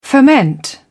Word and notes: foment: British English

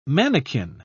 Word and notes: manakin: British English

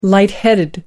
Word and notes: light headed: American English

